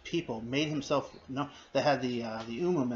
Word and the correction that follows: Urim: and the Thummim, or the Urim. Urim and Thummim, I always get mispronounced.